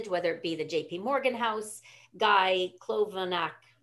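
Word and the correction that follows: Kolanovic: Yeah, Klonovic, yeah. – Klonovic, yeah.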